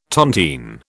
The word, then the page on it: tontine: British English